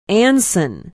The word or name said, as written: anson